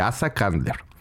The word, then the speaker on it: Asa Candler: La neta no sé ni cómo, cómo se escribe, güey, pero sé cómo se pronuncia. Y se pronuncia así, Asa Candler.